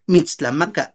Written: mitstlamaka

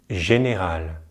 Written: général

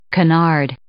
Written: canard

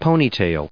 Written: ponytail

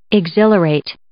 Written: exhilarate

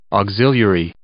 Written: auxiliary

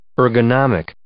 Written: ergonomic

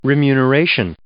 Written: remuneration

